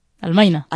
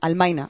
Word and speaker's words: Almaina: Recordarles que Rebeldes sin Sombra también se emite en otras radios libres, como Radio Bala en Manresa, Radio Almania en Granada. – Almaina. – Almaina, ay, que siempre lo digo mal, que me perdonen.